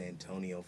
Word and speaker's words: Antonio: So, for the longest, I would spell it Antione, but some people didn't know how to pronounce that. So, then I just was like, you know what? I'm going by Desmond Antonio Fernandez.